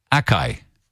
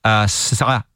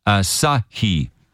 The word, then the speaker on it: Asahi: Well, it is a word that I, for years, have been pronouncing Akai. Akai. And of course, I'm completely and utterly wrong. The correct pronunciation for it, by all accounts, is Asahi. Asahi.